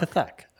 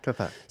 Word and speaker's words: Kathak: Yes, it does. Kathak. – Kathak, okay. – Kathak.